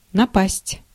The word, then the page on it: détourner: [de.tuʁ.ne]